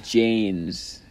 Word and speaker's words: Jain: A bindi is a colored dot worn on the center of the forehead, originally by Hindus and Jains. Am I saying that right? Jains? – Oh. – From the I think it's Jain, yeah.